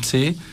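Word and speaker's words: qi: Η ενέργεια τσι, το είχαμε πει και στην προηγούμενη εκπομπή, η τσι, η κι λέγεται, στα ελληνικά πιο πολύ το προφέρουν σαν τσι, είναι μία ενεργώς ζωτική παύλα θεϊκή δύναμη η οποία διακατέχει και διαπερνάει τα πάντα.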